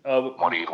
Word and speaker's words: Morir: Morir, which is spelled M-O-R-I-R.